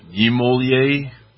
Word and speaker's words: Niemöller: How do you pronounce it? – Niemöller.